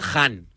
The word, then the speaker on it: Khan: He leído que se pronuncia Han. Si hay algún experto en persa que me corrija, pero en teoría pone que es Han.